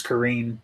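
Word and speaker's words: Qarin: No, if you look it up, I think it's Kareen, but it's Q A R I N.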